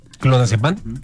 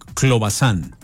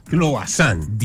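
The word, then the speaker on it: Clobazam: ¿Clonazepam? – No, se llama es como difícil el nombre. A ver, voy a repetir el nombre, sea Clobazán. – Clobazán.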